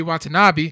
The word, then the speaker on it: Watanabe: So yeah, that one basically it follows the events of the first one, and then follows Spider-Man and Yuri and Yuri Watanabe.